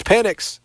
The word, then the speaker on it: Penix: He said big Penix energy. It's Penix. You can't change the pronunciation. – Yeah. – If the pronunciation was Penix, it's funny. But you can, phonetically, you are not allowed to alter the pronunciation of the name.